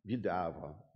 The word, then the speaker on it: Ville du Havre: And they left on the SS Ville, how do you say this in English? Ville du Havre? Ville du Havre, okay, in French.